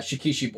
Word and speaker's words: shikishi: Shikshi board, I think it's called. It basically, let me make sure I'm saying that right. Yeah, Shikishi board from Barnes & Noble, and it has the art of a bunch of Viz manga.